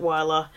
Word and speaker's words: Wylla: Alina Merrill, who was the mother of his bastard and um, Ned says that her name was Wylla, or maybe it's Willa, I'm not sure the pronunciation. In the show, they pronounced it Wylla, and he would soon, and he meant said this coolly, and he said that he would sooner not speak of her.